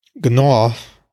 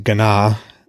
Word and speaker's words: Gná: Gná, although I pronounce it Gná in the chapter, is a woman who runs in to announce Odin's return.